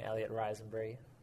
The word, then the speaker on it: Elliot Rysenbry: Elliot Rysenbry.